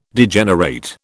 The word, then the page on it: Degenerate: British English